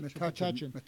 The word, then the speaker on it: Metuchen: In 2010, January 31st, was the opening of the cause of her canonization in Metuchen, is that how you pronounce that? New Jersey, at St. Francis Assisi Cathedral. – Metuchen.